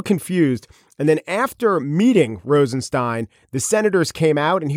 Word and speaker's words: Rosenstein: Good morning, I'm Rod Rosenstein.